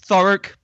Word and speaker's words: Thurrock: It's Thurrock, but yeah, it's just outside London, so basically, just go with London.